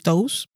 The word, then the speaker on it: Toews: Pues yo voy a decir Miriam Toews, no como el osito de las joyas, que está a su vez basada en un caso real que ocurrió en una colonia menonita en Bolivia.